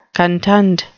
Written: contund

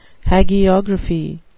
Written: hagiography

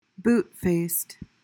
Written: boot-faced

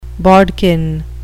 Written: bodkin

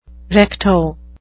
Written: recto

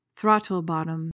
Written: throttlebottom

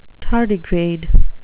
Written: tardigrade

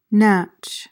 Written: natch

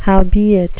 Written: howbeit

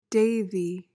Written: devi

